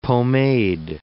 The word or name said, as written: pomade